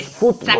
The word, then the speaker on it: fútbol: Nosotros no lo pronunciamos fútbol. – Como los argentinos, exacto, lo pronunciamos fútbol. – Como los argentinos, los españoles, los peruanos, ¿no? Casi todos ellos pronuncian esta palabra como muy cercana, como venía del inglés, como una palabra grave,